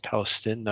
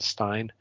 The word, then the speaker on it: Palestine: East Palestine, not Stein? – Yeah, East Palestine.